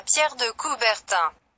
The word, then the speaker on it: Pierre de Coubertin: Estamos hablando del balón Pierre de Coubertin. Se pronuncia Pierre de Coubertin.